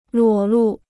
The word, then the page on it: 裸露: luǒ lù